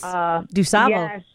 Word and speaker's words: DuSable: Yes, DuSable.